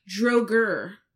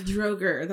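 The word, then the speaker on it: draugr: How did I pronounce this last time? – Hoobabaloo. – Droger. – I was totally off. – Droger. They're called drogers.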